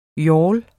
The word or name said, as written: yawl